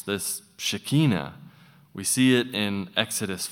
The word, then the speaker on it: Shekinah: And now that I can sort of read Hebrew a bit, I find that actually I've been saying it wrong all my life. It's Shekinah.